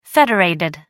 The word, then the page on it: federated: American English